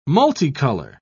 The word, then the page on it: multicolor: British English